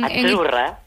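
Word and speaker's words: Azzurra: Azurra. Sí, como porque pizza. La Z no es la Z como decimos en catalán tan sonora, no. Ah, es azurra. – Muy bonito, muy bonito. Sí, es como pizza.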